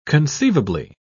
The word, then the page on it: conceivably: British English